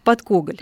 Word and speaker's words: Подкоголь: Подкоголь. – Подкоголь, да, правильное ударение.